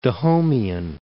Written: Dahomeyan

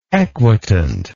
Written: equitant

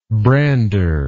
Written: brander

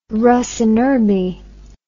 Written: rus in urbe

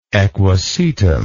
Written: equisetic

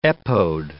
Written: epode

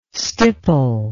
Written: stipple

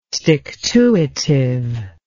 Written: stick-to-itive

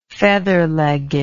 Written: featherlegged

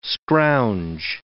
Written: scrounge

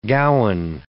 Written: gowan

